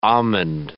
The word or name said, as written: almond